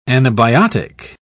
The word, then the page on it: anabiotic: British English